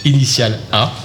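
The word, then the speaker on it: Initial_A: Thierry, dessinateur de bande dessinée, qui nous parlera de son dernier ouvrage, un super album qui s'intitule Initial A. J'ai bien prononcé c'est parfait. C'est bon.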